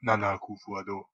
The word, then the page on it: Akufo-Addo: /æˈkʊfoʊ ɑːˈdoʊ/; a-KUUF-oh ah-DOH